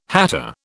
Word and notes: hatter: British English

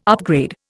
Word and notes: upgrade: Indian

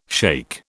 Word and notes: shake: British English

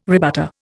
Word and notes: rebutter: Indian

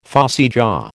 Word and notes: phossy jaw: American English

